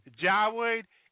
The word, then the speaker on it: Yahweh: Yahweh is the pronunciation of God's name. There are four letters. There you go. Praise Jesus, hallelujah. The pronunciation of those four letters is Yahweh, which a lot of people confuse and say it's Yahweh.